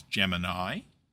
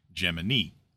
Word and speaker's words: Gemini: If you're like me, you desperately want to pronounce the name of this program as Gemini, but the NASA history is quite explicit that the project itself used the pronunciation Gemini.